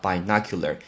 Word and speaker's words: binocular: 那个双筒的望远镜念成 binocular，binocular，b i n o c u l a r，哎，这个叫 binocular，因为那个是 b i b i 就代表二。